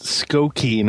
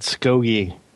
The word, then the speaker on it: Skokie: Oh, and it's pronounced Skokie, not Skogie.